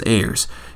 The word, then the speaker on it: Ayers: I believe it's Ayers. It's A-Y-E-R-S.